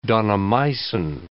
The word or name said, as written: daunomycin